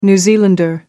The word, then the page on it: new zealander: American English